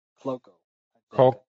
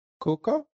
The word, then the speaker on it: Cuoco: Cloco. – It's C-U-O-C-O. Cuoco?